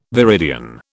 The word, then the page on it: viridian: British English